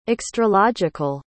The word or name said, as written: extralogical